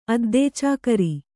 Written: ಅದ್ದೇಚಾಕರಿ

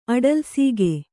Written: ಅಡಲ್ಸೀಗೆ

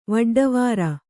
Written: ವೊಡ್ಡವಾರ

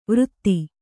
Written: ವೃತ್ತಿ

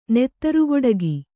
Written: ನೆತ್ತರುಕೊಡೆಗೆ

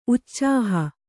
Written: ಉಛಾಹ

